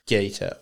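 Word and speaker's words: Geto: Yeah, the way it looks like Gito... But it's pronounced Gato.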